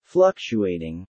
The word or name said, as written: fluctuating